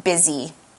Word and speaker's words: busy: Now, busy is another adjective, and it's spelled B-U-S-Y, busy. We're talking about the adjective busy. Now, you might notice that the spelling of this adjective is quite different from how it's pronounced. We pronounce it, instead of using an S, we use a Z sound. So we say busy. And instead of saying an uh for the U sound, we use a short I sound like this, busy.